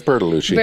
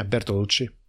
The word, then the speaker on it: Bertolucci: Yeah, it's Bertolucci. – Bertolucci, is that how you say it? – Yeah, Bertolucci.